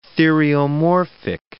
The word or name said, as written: theriomorphic